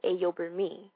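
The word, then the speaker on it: Ayoburmi: My full name is Ayo Burmi.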